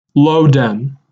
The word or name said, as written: Lowden